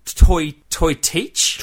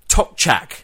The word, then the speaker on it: Tokchak: If you just read it off the box, it would be kind of look like toy teach. – Toy teak? Tortoik. – But as we have discovered, the correct pronunciation for this one is the Tokchak.